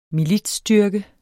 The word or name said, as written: militsstyrke